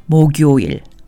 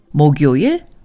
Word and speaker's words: 목요일: 목 followed by 요일 is 목요일. It's pronounced 목요일, which is Thursday. 목요일, 목요일, 목요일.